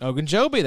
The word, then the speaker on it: Ogunjobi: Larry Ogunjobi. – Ogunjobi, that's it.